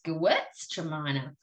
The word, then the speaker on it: Gewürztraminer: Tremina's the end, but it's Gewurz Tremina.